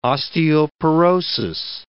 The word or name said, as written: osteoporosis